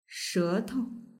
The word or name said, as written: shetou